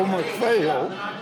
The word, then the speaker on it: McPhail: My name is Joe McPhail. It's spelled M-C-P-H-A-I-L.